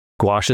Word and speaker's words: gouache: I got some, I don't know how to pronounce it correctly, gouache. – Yeah, gouache.